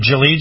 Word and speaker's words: Gilead: And they are going to be using, here it is, I believe I'm saying this right, Gilead Services, G-I-L-E-A-D. Maybe it's Gilead.